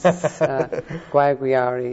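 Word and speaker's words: Guayaguayare: Guanaguana, is that right? I'm trying to remember how to pronounce some of the names. – I don't know. Guayaguiari. It's hard to pronounce. Guayaguiari, yes. It's further south than Mayaro that I spoke about.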